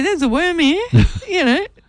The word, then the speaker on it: Wurmhere: How about Worm here? – What? How do you spell it? – W-U-R-M-H-E-R-E.